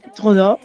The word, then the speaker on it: Trudeau: Pero es que también, si haces un poco más grande el abanico, te salen otros nombres, como por ejemplo Justin Trudeau, si es que se dice así. – Sí, Trudeau, bueno, es que yo francés, por favor, qué vergüenza.